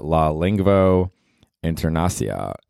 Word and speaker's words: La Lingvo Internacia: I guess in Esperanto, you would pronounce that or say that La Lingvo Internacia.